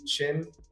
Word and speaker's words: Chin: Man, at Jamie, J-H-A-M-I-E, Chin, C-H-I-N, spelled regularly.